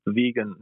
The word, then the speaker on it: Vegyn: Vegan. It's spelled V-E-G-Y-N.